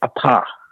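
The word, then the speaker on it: part: The last word is actually, I mean, it's French, so it's pronounced a pas. Like, you forget the, you know, ignore the T.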